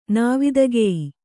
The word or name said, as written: ನಾವಿದಗೆಯ್